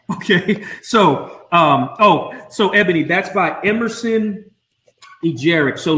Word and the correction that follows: Eggerichs: And I hope I'm pronouncing that right. But it's Eggerichs. There you go.